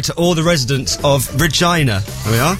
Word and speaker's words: Regina: I was listening to Dear Scott, and there was someone from my neck of Canada, and the posh Radio 2 lady pronounced the place wrong. Regina. It rhymes with a lady's girly bits.